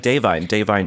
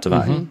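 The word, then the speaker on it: Da'Vine: Like Davine Joy Randolph, right? – Yeah. – It's not Divine. – Mm-hmm. Yeah. Davine.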